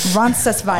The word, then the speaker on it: Roncesvalles: So when you start in San Jean, you end up in a village called Roncesvalles. I'm saying that wrong for anybody who wants to come at me. – That sounded right. – Thank you. – I know, you could have fooled me. – Roncesvalles.